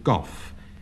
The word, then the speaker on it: golf: John Betjeman pronounces this game goff, feeling that the L is entirely superfluous, and I agree with our best poet.